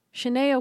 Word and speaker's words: Sinead: So, I don't know how to say this lady's name. Do you know how to say it? Sinead? Child, I'm about to do a Sinead. Sinead O'Connor. It's like Sinead. – Is that really how you say it? I hate myself. Mm-hmm. That's how you say it. So, Sinead, S-I-N-E-A-D. She's an R&B singer.